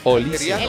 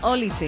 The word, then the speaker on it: Olise: Yo tengo con tilde en la I. – Con la I. – Acento en la I. Olise. – Él lo dijo, Michael Olise.